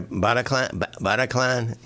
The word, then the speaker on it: Bataclan: Bataclan? B-A-T-A-C-L-A-N, Bataclan Theater.